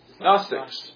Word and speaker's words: Gnostics: Starts with a G, a silent G. Gnostics.